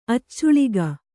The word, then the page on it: ಅಚ್ಚಾಳಿಕೆ: kannada